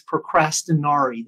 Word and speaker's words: procrastinari: Procrastination in Latin means procrastinari. That's the pronunciation of the word.